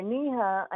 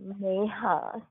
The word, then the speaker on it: Neha: So when a lot of like, especially white folks fuck it up and call me Nina or Neha, I am very pleased to say, my name is Neha. Say it right, because the stars chose it for me.